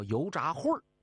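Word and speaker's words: 油炸桧儿: 那临安的老百姓从此以后把那油条，我们东北叫大果子，改成叫油炸混儿。啊，为啥叫油炸混儿呢？油炸混儿啊，那个混儿和秦桧的桧加儿化音是一个读音，那意思就是恨死你了，炸了你。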